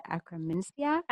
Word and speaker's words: Akkermansia: Akkermansia.